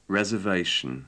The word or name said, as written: reservation